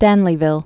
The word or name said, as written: Stanleyville